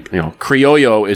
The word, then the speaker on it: Criollo: And the filler is a blend of Pennsylvania, Nicaraguan Esteli, and Corojo and Criollo, which is actually, Criollo is how you pronounce it, but it's Criollo 98 fillers.